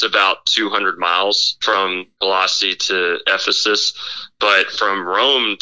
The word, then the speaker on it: Colossae: And so anyway, okay, so it's called Colossae. That's what it is. And so I'm not really good at pronouncing Greek and Roman words still, but anyway, it's called Colossae.